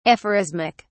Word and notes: aphorismic: British English

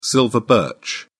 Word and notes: silver birch: British English

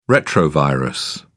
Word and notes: retrovirus: British English

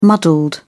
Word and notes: muddled: British English

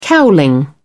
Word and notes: cowling: British English

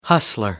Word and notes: hustler: American English

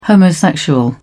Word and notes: homosexual: British English

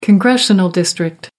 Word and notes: congressional district: American English

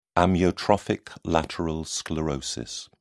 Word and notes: amyotrophic lateral sclerosis: British English

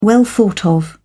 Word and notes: well-thought-of: British English

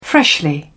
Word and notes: freshly: British English

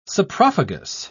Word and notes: saprophagous: British English

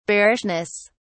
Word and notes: bearishness: British English

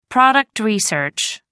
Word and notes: product research: American English

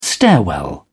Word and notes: stairwell: British English

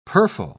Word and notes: purfle: British English

